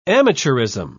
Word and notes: amateurism: British English

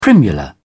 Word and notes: primula: British English

